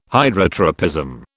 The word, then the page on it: Hydrotropism: British English